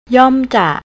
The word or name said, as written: yomja